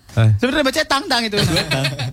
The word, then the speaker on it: Tintin: Tintin tuh Tintin. Eh enggak enggak, Tintin tuh tang tang aslinya. Ah, serius. Stawa. Aslinya tuh Herge itu Adventure of Tang Tang.